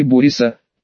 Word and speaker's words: Бориса: Назывался он у дяди Бориса, причем ударение падало на первый слог.